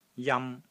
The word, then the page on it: JAM1: Cantonese